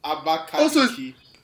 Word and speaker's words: Abakaliki: It's Kiliki, not Kaliki. – What is Kiliki? It's Liki. – Wait. It's Licky. – Abakaliki. – Oh, she's a me. I've been pronouncing it wrong all these years.